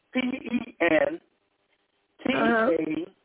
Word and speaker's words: Pentateuch: It's pronounced Pentateuch.